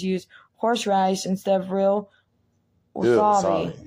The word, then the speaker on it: wasabi: Most swabby paste isn't real swabby. I think I pronounced that wrong. – Well, you know what? Close enough. It's wasabi.